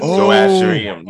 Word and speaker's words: Zoroastrian: Zoroastrian? – I can't say it. – Yes. – Oh, I wish I could say it. – Zoroastrian. Yeah. – Zoroastrian? – Yeah.